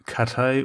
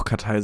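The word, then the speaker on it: Cathay: Ganz genau, nach Kathay oder Kathy. Welche Aussprache ist dir lieber? Ich finde Kathay besser, glaube ich. – Ich würde auch Kathay sagen, ja.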